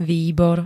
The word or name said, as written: výbor